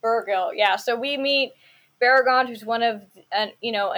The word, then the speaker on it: Beregond: And then finally, we also meet Beregond. Um, and is that how we say it? Is that how we say his name? – That's how I've always said it. – Beregond.